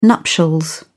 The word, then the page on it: nuptials: British English